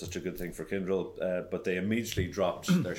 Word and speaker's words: Kyndryl: But I guess the biggest news, really, on the IBM side of the house is that Kyndryl, if I'm spelling that right or pronouncing that right, yep.